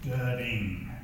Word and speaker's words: Dudding: It's Dudding, like D-U-D, and then D-I-N-G, Dudding.